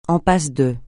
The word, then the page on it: en passe de: français